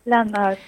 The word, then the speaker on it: Land Art: Land Art, sí.